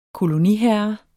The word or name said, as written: koloniherre